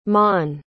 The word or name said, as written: mon